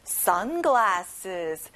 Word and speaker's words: sunglasses: sunglass, sunglasses. 친구들, sun에 강세를 넣어보고요. glasses예요. 알겠죠?